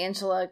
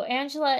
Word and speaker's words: Angela: I'm going to say Angela because I feel like I'm mispronouncing Angela. But they have a hard G when they say her name. It sounds much prettier in Spanish.